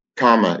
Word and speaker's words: kama: Kama is not like karma as in the law of cause and effect, it's actually pronounced kama, K A M A, and it means desire.